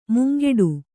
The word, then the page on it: ಮುಂಗೆಡು: mungeḍu; kannada